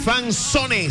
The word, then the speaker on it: fanzone: Fan Z-O-N-E. Fanzone, se escribe así. Se pronuncia fanzone.